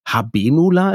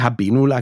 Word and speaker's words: Habenula: Habenula, ist das richtig ausgesprochen? – Ja, würde ich auch so aussprechen.